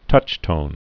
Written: touchtone

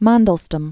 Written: Mandelstam

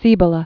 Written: Cibola

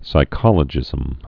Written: psychologism

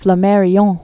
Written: Flammarion, Camille

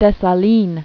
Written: Jean Jacques Dessalines

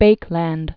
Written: Leo Hendrik Baekeland

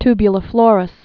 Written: tubuliflorous

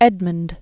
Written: Edmund I

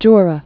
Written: Jura Mountains

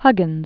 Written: Huggins

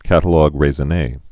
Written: catalogue raisonne

